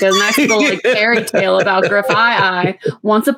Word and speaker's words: Grafaiai: Grafaiai, because it's kind of like graffiti, because it's a graffiti thing, so yeah, it's got to be Grafaiai.